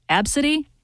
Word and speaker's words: Abcde: A-B-C-D-E is how it's spelled. It's pronounced Absidy.